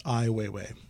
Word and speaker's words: Ai Weiwei: Correct pronunciation, just as a correction, is Ai Weiwei.